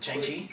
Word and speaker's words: Shang-Chi: Shang-Chi? – Yes. – What's Shang-Chi? – Shang-Chi. – Shang-Chi? – Shang-Chi. – You didn't say that. – Shang-Chi? – No. – That's how you pronounce the name.